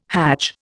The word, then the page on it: hadj: Indian